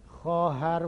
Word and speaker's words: خواهر: میگه این اختلاف بین خواهر و خانه در نوشتن، این تلفظ ما غلطه. یعنی تلفظ در قدیم خواهر مثل خانه نبوده است.